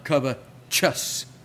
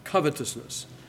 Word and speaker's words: covetousness: You see that there's no E following the T. There's an E before the T, but no E following the T. That means the right pronunciation of the word is covetousness, not covetousness.